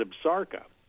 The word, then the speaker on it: Absaroka: And the actual pronunciation for the term, which is a Crow word for themselves, which means the children of the long-beaked bird, is Absaroka.